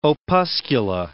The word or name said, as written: opuscula